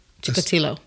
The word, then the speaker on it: Chikatilo: I can't pronounce his last name. – What is it? – So, Andre Chikatilo. – That sounds about right.